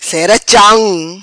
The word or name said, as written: Cera Cham